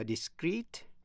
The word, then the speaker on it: discrete: Discrete, D-I-S-C-R-E-E-T là giữ kín. Và discrete, D-I-S-C-R-E-T-E là rời rạc. Phân tách. Phát âm như nhau, discrete.